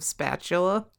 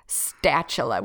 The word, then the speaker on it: Statula: Did you say his name was Joseph Spatula? – Statula with a T.